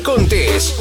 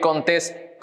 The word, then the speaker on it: Contés: Esto es Mi Rutina de Trabajo con Miguel Contés, con acento en la E. Bienvenido a otro episodio de Cereal Empresarial Podcast. Mi nombre es Miguel Contés